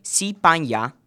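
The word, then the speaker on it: si pan ya (西班牙): El país España, sí, se dice si pan ya.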